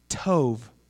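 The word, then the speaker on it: tov (טוב): The Hebrew word actually is tov, right? It's spelled T-O-W-B, which it sounds like T-O-V-E, tov.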